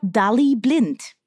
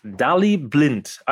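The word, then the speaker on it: Daley Blind: Ähm, und jetzt hat sie eines gelernt, nämlich die richtige Aussprache von dem neuen Mann beim FC Bayern. Der heißt nämlich wie? – Mir wurde gesagt Dali Blind. – Dali Blind.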